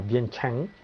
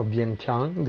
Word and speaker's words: Vientiane: Leggendario fiume Mekong, arriviamo a Vientiane, o Vientiane, dipende dalla pronuncia francese o laotiana, la capitale del Laos.